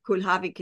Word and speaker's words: Kulhawik: It's actually Kulhawik. Kulhawik is the real Polish pronunciation, yes. – But we anglicized it.